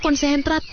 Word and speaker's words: konsentrat: Soman 2 con senat. – Bacanya konsentrat, Pak.